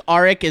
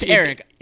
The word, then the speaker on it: Aric: I've heard it both, and what's funny is I've heard it both ways from creators. So it's stupid. I think Arik is the more common. – Because it is spelled A-R-I-C. – So yeah, it's Eric.